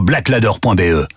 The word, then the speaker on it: Blaklader: Va vite voir sur blackladder.be. Black Ladder, ça s'écrit BLAKLADER.